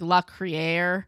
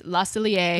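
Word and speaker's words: Le Cellier: So it looks like La Criere, but it's La Cellier.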